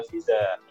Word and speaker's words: Pfizer: Pfizer. Ya gitulah ya. – Pfizer.